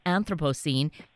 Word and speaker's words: Anthropocene: And I am so grateful because this new book, Living in the Anthropocene, and I'm saying that correctly, is that right? – That's right. Yeah. There are a number of ways to say it, but the way you pronounced it is fine.